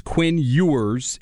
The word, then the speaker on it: Quinn Ewers: It's Quinn Ewers, and he's from South Lake Carroll, 6'3", 195.